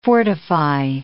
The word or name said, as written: fortify